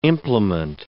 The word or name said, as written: implement